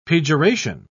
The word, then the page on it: pejoration: British English